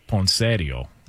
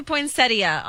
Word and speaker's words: poinsettia: Well, the in-laws would call it poinsettio. – Oh, gosh. Okay. – What up? You know what I'm saying? Can we get the proper pronunciation on this? – It does say poinsettia online.